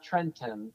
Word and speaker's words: Trenton: So, for example, our capital is spelled T-R-E-N-T-O-N. So there's that second T. I don't pronounce second T's. – Mm-mm. – So it's not Trenton, it's Trenton.